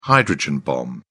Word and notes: hydrogen bomb: British English

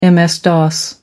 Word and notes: ms-dos: American English

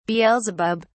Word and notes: beelzebub: British English

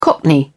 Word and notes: cockney: British English

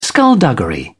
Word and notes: skulduggery: British English

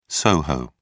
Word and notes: soho: British English

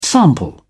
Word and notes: sample: British English

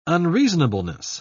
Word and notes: unreasonableness: British English

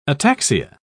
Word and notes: ataxia: British English

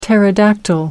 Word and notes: pterodactyl: American English

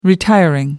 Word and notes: retiring: American English

